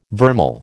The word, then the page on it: vermeil: American English